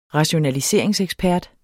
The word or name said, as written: rationaliseringsekspert